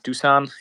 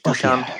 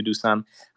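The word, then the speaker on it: Dušan: Is it Dusan? – Yes, yes, it's correct. It's Dusan in Slovak language, Dusan, but I used to Dusan.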